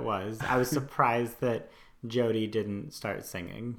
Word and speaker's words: Jyoti: but I believe it's Jody. – So, yeah, I think you're right, Jody. – Jody. So it's spelled J-Y-O-T-I.